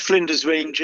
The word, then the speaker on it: Flinders Range: Is it the Flinders Range? Am I saying that right? – The Flinders Range is.